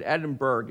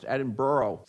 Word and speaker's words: Edinburgh: A lot of you, by the way, when you see that word, you pronounce it Edinburgh. It's not pronounced that way. It's pronounced Edinburgh, Scotland.